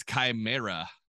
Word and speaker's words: chimera: They are known as chimera. – Ah, that's how you pronounce it.